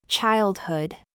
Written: haha

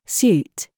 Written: GOOSE

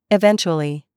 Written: CHURCH

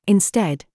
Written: THIS

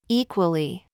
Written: WE

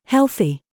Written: THINK

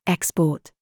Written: THOUGHT

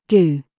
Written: GOOSE

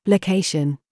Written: GOAT

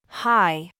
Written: haha

